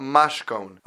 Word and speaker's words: mashkon: can be pronounced as a Mashkon.